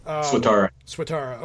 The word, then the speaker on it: Swatara: Swatara.